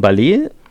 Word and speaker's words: Ballet: Das ist von Ballet, heißt die Band. Und reimt sich auf Array. – Ja, weil das anders ausgesprochen ist. Das ist ja französisch ausgesprochen.